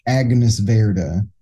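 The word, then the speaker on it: Agnès Varda: Agnes Verda.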